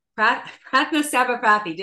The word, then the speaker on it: Prathna Sabapathee: Prathna Sabapathee.